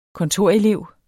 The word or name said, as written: kontorelev